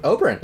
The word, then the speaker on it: Oberyn: It's Oberyn? – Oberyn, yeah.